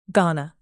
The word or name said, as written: ghana